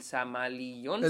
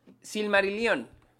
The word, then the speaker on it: Silmarillion: Para, para pronunciar el título de este, el, el Samalillón. – El Samalillón. a, ver. ¿quién lo dice primero? Bien. ¿Semarillón? – Silmarillion.